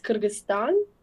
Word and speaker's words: Кыргызстан: У нас э, в России все, вот у меня никак не укладывается, Киргизстан или Киргизия? Вот как правильно говорить? – Э, вообще правильно будет говорить Кыргызстан, Эм, угу.